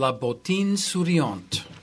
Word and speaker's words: La Bottine Souriante: It's La Bottine Souriante.